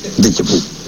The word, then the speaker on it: déjà vu: El mismo, ¿cómo se pronuncia? ¿De jabú o de chapú? De chapú.